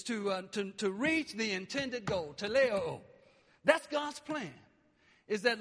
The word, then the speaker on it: teleioo: It's teleo. Okay? Teleo. It's spelled T-E-L-E-I-O-O. Teleo.